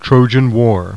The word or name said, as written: Trojan